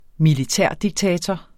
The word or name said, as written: militærdiktator